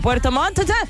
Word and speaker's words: Puerto Montt: Hay que pronunciar bien porque Puerto Montt estoy con dos T.